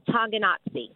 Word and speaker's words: Tonganoxie: How do you pronounce the city where you live? – It is Tonganoxie. – Tonganoxie.